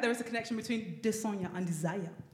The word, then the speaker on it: Desanya: One of them, the name is spelled D-E-S-A-N-Y-A. How do you pronounce that? – Desanya. – Desanya, okay.